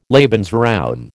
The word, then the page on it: Lebensraum: American English